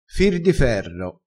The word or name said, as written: fir di ferro